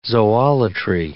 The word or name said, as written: zoolatry